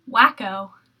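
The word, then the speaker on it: Waco: You know what's spelled like taco, but isn't pronounced like it. – Wacko. – Yes, Wacko, Texas.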